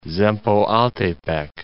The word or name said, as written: Zempoaltepec